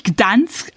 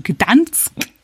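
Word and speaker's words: Gdańsk: Also Danzig spricht sich Gdansk aus, also Gdansk hier auf Polnisch.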